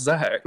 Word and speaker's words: Zac: It's still pronounced Zach.